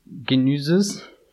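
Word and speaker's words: Genisys: Sag's, wie wird er ausgesprochen? – Genüses.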